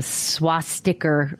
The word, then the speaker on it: swastika: Hang swastika. On. Swastika. Not R. You don't put an R on the end of it.